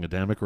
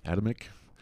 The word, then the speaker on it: Adamic: It's so impressed upon me lately, mostly about the Adamic curse. – Uh-huh. – If I'm pronouncing Adamic right. – Sure. – Adamic.